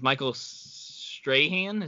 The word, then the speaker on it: Michael Strahan: My favorite part about the booing, though, was Michael Strahan.